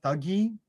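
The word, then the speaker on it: Thuggee: Thuggy? – They said thuggy in the movie.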